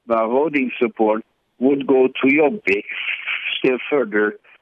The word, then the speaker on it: Jobbik: The Jobbik Party? – Well, yeah, it pronounces Jobbik.